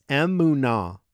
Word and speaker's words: emunah: And the word that describes this is emunah. Emunah. You can spell it E-M-U-N-A-H.